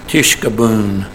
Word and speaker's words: tishkabun: And that final word of the chapter, it's a rather long Hebrew word, tishkabun. But the emphasis on the last syllable, the boon, the tolling sound of the bell, like a death knell.